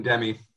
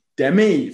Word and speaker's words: Demme: Jonathan Demme. – Demme, thank you, because I was not going to say it that way. So I'm glad you bailed me out. Jonathan Demme.